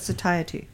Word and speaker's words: satiety: Yeah, how do you say that with satiety? – Satiety, that's the word, like in high satiety.